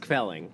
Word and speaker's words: kvelling: if you want some more kvelting, come and just give me a ring. – It's pronounced kvelling.